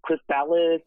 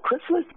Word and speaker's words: Chrysalis: Are we even pronouncing it right? – Yes, actually, yes, you are. It's really funny, because like some people are like, Chrysalis, or like, what does it even mean? So Chrysalis is how you pronounce it.